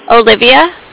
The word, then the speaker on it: Olivia: My name is Olivia. I'm with the Bank of Cardiff. And Cardiff is spelled like C as in cat, A. – Okay, what is your name? I'm sorry. – Olivia. O-L-I-V-I-A.